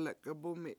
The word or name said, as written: lekabUmi